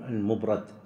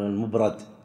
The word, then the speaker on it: المبرد: بعض ينطقه المبرد، المبرد، وبعض يقول المبرد، يقول لأن يده كانت يعني هو أو غيره كانت خشنة جدا، فيقول المبرد. نعم.